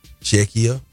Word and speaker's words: Czechia: Anyway, today, 12 noon, Czechia. I think I'm saying it right. – Yeah, that's it.